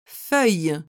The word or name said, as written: feuille